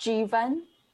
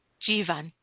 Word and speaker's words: Jivan: I was just going to say that it's pronounced Jivan. – Jivan.